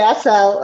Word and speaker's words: Assel: And I have with me a seal. Did I say it right? – That's okay. A cell. – Oh man, a cell.